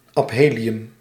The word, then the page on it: aphelium: IPA: /ɑp.ˈɦeː.li.ʏm/; Dutch